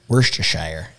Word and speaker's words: Worcestershire: Worcestershire. Worcestershire.